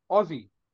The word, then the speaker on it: Aussie: Say it like Ozzy Osbourne for Black Sabbath. Aussie. That's how you say it.